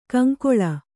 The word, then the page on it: ಕೌಂಕುಳ್: kannada